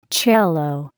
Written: cello

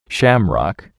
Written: shamrock

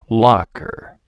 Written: locker

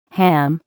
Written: ham

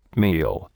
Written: meal

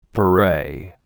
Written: beret

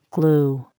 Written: glue

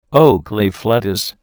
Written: oakleaf lettuce

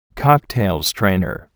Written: cocktail strainer

